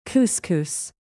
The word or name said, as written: couscous